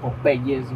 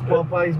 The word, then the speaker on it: Popeyes: Popeyes, no sean gaterres y no le digan Popeyes, güey. No se dice así, güey. Es Popeyes, vamos por el primer chicken sandwich.